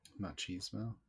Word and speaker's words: machismo: Machismo.